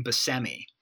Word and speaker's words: Buscemi: Steve, first of all, I learned that it's Buscemi, weirdly, but he pronounces his last name Buscemi. I also always assumed it was Buscemi.